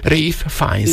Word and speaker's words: Ralph Fiennes: Na, ez érdekes, ahogy ezt kiejtetted, ezt a nevet, mert ennek a színésznek a nevét... – Ralph Fiennes. – Így kell ejteni, igen, ugye?